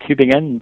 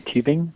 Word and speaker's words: Tübingen: Yeah, you think it sounds like Tübingen, but it's actually Tübingen.